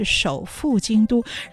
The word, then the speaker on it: 首赴京都: 首赴京都，春草念错了，嗯、念成首府京都。嗯、然后小姐说：“ 不是，你念错了，是首赴京都。